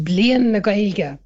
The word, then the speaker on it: Bliain na Gaeilge: That's in Irish, that would be pronounced Bliain na Gaeilge.